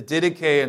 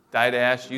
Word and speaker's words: Didache: The Didache, and some pronounce it Didache, you say tomato, I say tomato.